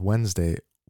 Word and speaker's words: Wednesday: He says he pronounces Wednesday Wednesday. And I pronounce Wednesdays like how apparently Americans pronounce Wednesdays. And you can hear me pronouncing it right now, like Wednesday. So I say W E N instead of Wednesday.